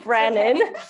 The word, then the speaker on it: Branin: Branin.